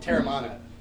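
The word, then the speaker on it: Teremana: Terramanis. I think it's pronounced Terramanis. – There's no S on it. – Terramana.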